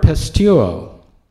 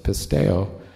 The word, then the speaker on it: pisteuo: I just realized I've been pronouncing it wrong for about 10 years. It's very humbling. The Greek word pisteuo, P-I-S-T-E-U-O. The first time I ever heard somebody teach on it, they pronounced it pisteo, and that's what I've been doing for 10 years. And if all else fails, look it up.